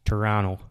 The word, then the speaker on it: Toronto: Toronto, it's pronounced. Toronto. – Toronto. – Toronto. – No second T.